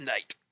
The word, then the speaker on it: Knight: He plays Day and she plays Knight. It's one of those movies. – Did he say Knight? – Yeah, he did. – Yep. – Oh, the K is silent.